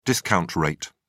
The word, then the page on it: discount rate: British English